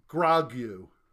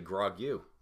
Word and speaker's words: Grogu: Sounds like right? G R O G U. How would you say that? – Well, someone pissed me off, I'd say grog you. – No, that's not its that's not name.